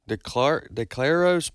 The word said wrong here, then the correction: DeCarlos: Brown, how do you pronounce his name? – DeCarlos Brown, 14 times he's been to court for arrest.